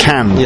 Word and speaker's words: Cannes: So it's actually pronounced Cannes. – Yeah, that's Cannes.